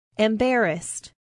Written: embarrassed

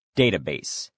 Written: database

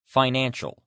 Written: financial

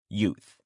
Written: youth

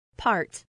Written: part

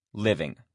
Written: living

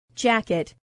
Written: jacket

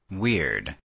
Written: weird

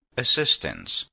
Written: assistance

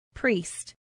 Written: priest

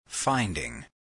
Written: finding

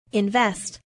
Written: invest

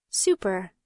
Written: super

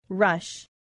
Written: rush

